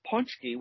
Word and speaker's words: pączki: It's P-A-C-Z-K-I, and it's pronounced punchki, which is the plural. The singular would be punchek.